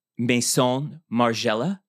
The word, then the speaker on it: Maison Margiela: So we have, I think this dress was actually Maison Margiela.